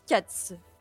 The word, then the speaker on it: Kac: parce que ça s'écrit Cac, K-A-C, mais ça se prononce apparemment Katz.